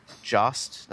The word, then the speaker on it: JAST: that's J-A-S-T USA. An English iOS release is also due out in September. Jost, that's how it's pronounced.